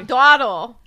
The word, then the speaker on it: dawdle: Don't dwaddle. – I like saying it wrong. – Wait, you genuinely thought the word was dwaddle? – Yes.